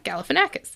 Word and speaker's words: Galifianakis: Disney's live-action Lilo and Stitch movie is beginning to shape up with the casting of Zach Galifianakis. Am I saying his name right? – Mm-hmm. Okay. Yeah, you got it.